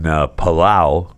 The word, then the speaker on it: Palau: He spent 14 days in a hotel quarantined due to an outbreak in Palau, right? P-A-L-A-U, I'm pronouncing that right.